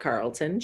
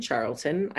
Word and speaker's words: Charlton: And shortly after this time, he met and married his wife, Lula Belle Charlton, not Carlton, Charlton. I had to like phonetically write it out because I kept wanting to say Carlton.